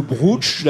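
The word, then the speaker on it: brocciu: Le brochou ou le brooch, d'ailleurs, je crois qu'on peut le prononcer aussi comme ça.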